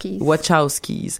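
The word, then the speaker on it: Wachowski: des Wachowskis. Je le prononce pas comme il faut, hein. – Wachowskis.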